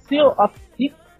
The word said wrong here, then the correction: Sea of Thieves: Como é que pronuncia? – Sea of Thieves. Eu chamo de Sea of Thieves, porque os piratas são lá, velho.